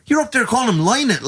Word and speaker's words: Lynott: It's Lynott. Don't ever say Lynott. Phil Lynott, lad.